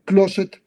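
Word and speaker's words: Closset: Fetidez corporal la describen dos de sus médicos al final de sus vidas, eh, uno de ellos el doctor Closet, como el closet de la casa, así se pronuncia, y el otro el Bon Salaba, Salaba con doble L.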